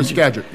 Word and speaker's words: Skagit: Upper Skagit, yes, the Upper Skagit case.